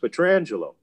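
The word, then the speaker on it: Pietrangelo: And Mike said, well, you can believe that if you want, but if you ask him how he pronounces his name, he'll tell you it's Alex Petrangelo. And not long after that, Mr. McGuire acquiesced and started calling him Alex Petrangelo on the air.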